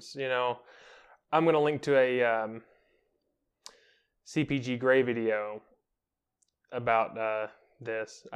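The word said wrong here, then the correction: CGP Grey: Did I get the name right? – Yes. No, you did not get the name right. – It's CGP Gray? – CGP Gray. – CGP Gray. Okay.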